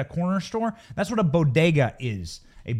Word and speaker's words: bodega: Jill Biden butchered the pronunciation of bodega. Bodega.